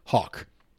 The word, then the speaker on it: Haack: And that is the Bills signed former Miami Dolphins punter, Matt Hawk, H-A-A-C-K pronounced Hawk.